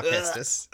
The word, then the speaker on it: Kestis: Cal Kestis.